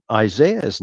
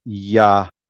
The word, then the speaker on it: Yahziah: And just like Isaiah's name is, it's Yahziah, Yahziah. And so we kind of error a little bit using our English vernacular, and we say Isaiah, but Yahziah is the true Hebrew pronunciation.